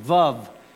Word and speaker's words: vav: In the third letter, it looks like a kind of a pointy finger at an angle, is a vav. It's our V sound, vav. So, and then you have the exact same letter repeated over. So it's yod hey, vav hey.